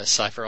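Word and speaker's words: Seifer: Cipher sounds right. It rhymes with Kiefer.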